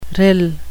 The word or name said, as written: rill